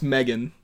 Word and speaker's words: M3GAN: It's pronounced Megan.